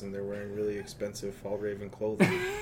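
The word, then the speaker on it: Fjällräven: Fall Raven stores. – Is that actually how you pronounce it? – Yeah. – Fjall Raven? Yeah, I guess.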